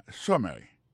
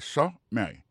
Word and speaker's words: summary: Par exemple, sous premier mot et summary, pour question de prononciation, accent tombé sous premier syllabe, summary. Ça, c'est summary.